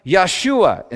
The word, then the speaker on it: Yeshua: In Hebrew, salvation is pronounced Yeshua in this verse, which sounds just like the name Yeshua, which literally means God is salvation.